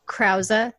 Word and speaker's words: Krause: Krause, thank you.